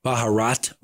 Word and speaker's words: Baharat: Baharat with a T at the end? – Yeah, with a T at the end.